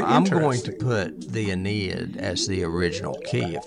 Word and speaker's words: Aeneid: Adamantium, it was actually first mentioned in the Enid, I think is the way you pronounce it, A-E-N-E-I-D, The Aeneid. – Aeneid.